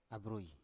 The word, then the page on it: avroi: Biak